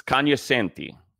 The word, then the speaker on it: cognoscenti: It's cognoscenti. The G is silent.